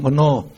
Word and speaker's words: mano: O is pronounced O. No, pronoun mano.